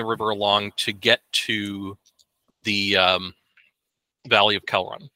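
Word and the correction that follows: Kidron: Kedron, excuse me. I keep wanting to mispronounce that.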